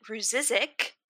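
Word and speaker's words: Rzucek: Shanann Catherine Ruzizek, and yes, I looked up how to say that. That is how it is pronounced.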